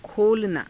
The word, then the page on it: kholanaa: Hindi